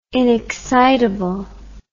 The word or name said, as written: inexcitable